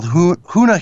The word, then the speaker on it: Huna: It's called Huna Healing.